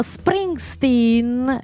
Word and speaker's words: Springsteen: Adolescente di primavera, anche detto Springsteen, visto come l'ho detto bene.